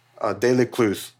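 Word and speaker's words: Delescluze: which include Charles de Clouse. Delecluse, the better pronounced.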